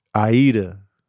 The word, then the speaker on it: Aida: Now, if indeed Ida is set in that Victorian era, maybe it could have been like an extra or like a stagehand or an understudy. Aida. Oh, that's how you say it?